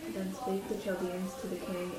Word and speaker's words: Chaldeans: Chaldeans. Chaldeans